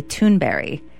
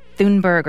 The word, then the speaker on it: Thunberg: Say that the correct pronunciation of her last name is actually Thunberry, not Thunberg or Thunberg.